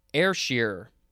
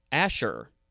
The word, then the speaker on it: Ayrshire: All right, next we have Ayrshire, or Asher. I think all of those are fine. It is spelled capital A-Y-R-S-H-I-R-E.